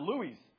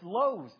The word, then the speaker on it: Lowe's: He said, "I don't know how to pronounce it." I said, "It's Lowe's." I said, "You're the weirdo now."